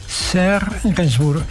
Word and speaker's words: Serge Gainsbourg: ¿Lo pronunciado bien? Serge Gainsbourg, perfecto.